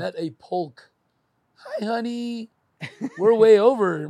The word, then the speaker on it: Polk: Shout out to Doug Polk. – Doug Polar? – Polk. – Polk. – P-O-L-K. – And he says that he pronounces the L.